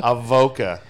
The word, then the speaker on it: Avoca: Is that how you pronounce the city? Avoca? – Avoca.